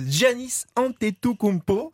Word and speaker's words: Giannis Antetokounmpo: Euh, Giannis Antetokounmpo.